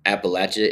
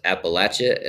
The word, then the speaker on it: Appalachia: What I like to tell people is that Appalachia is pronounced Appalachia and not Appalachia. It's like throw an you.